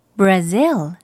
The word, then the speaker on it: Brazil: Brazil. – Brazil 이렇게 발음합니다. 우리말로는 그냥 브라질이라고 하지만 영어로는 또 zil 부분을 강하게 발음하면 돼요.